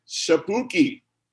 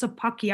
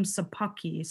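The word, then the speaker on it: Szapucki: They called up my man Shapuki. – Yeah. You know, actually, if I'm not mistaken, I think it's pronounced Sapucky. I was listening to the Mets broadcast and Gary Cohen called him Sapucky.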